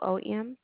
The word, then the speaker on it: Boone: Landis Boone. – Spell that first name, please. – Landis. – And the last name was Boom. Is it Boom? – Boone.